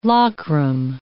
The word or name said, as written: lockram